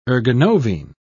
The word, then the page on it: ergonovine: British English